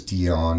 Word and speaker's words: Dion: But my name is Dion, and it rhymes with peon.